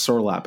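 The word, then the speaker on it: Sørloth: I just like Sorloth. And I actually think it's pronounced Sorloth, but I don't like the way that sounds, so I'm going to keep saying Sorloth.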